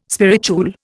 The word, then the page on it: spirituel: Indian